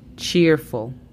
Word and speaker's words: cheerful: La pronunciación fonética sería CHIURFL. Cheerful.